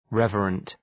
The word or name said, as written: reverent